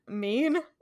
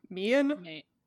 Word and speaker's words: mien: And it's mean, M-I-E-N. Edward says that Bella's mean changed. And I was like, the fuck is that? – Who's mean? – I googled it and hit the pronounce button. I would have said mean.